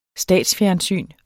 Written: statsfjernsyn

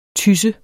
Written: TYSSE

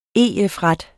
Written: EF-ret

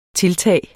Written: TILTAGENES

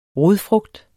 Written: rodfrugt

